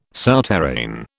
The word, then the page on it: souterrain: British English